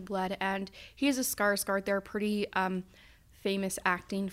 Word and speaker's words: Skarsgård: Yeah, Skarsgård.